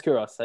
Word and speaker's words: Kouros: Um, now I'm going to butcher this name as always. I think his name is Yanis Kouros. Yeah. – Yanis is that Kouros. It? Yeah, Yanis Kouros. I, I, got the first name right.